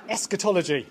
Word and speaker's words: eschatology: If you want to pronounce that, eschatology.